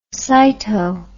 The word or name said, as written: sito